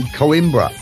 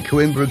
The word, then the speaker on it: Coimbra: They're from Coimbra. I don't know how to say it. Coimbra. And do you know what's amazing about Coimbra, Coimbra, whatever you call it? – No.